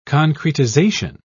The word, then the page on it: concretization: British English